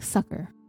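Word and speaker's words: succor: It's spelled S-U-C-C-O-R. I was like, sucker. I looked it up and it's just like sucker. That's it, like the candy sucker.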